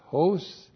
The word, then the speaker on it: hos: H, long O-S, hos.